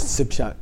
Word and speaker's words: Tsishak: The spelling is Tsishak and the colloquial is Tsipshak. Because they pronounce the prefix letter of the second syllable.